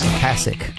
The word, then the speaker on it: Ocasek: Ocasek.